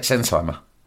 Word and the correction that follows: Sennheiser: Sennheiser? – Sennheiser.